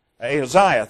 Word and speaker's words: Ahaziah: A son, Jehoram, and a son, Ahaziah. Did I pronounce that right? Ahaziah, thank you. I knew that didn't sound right.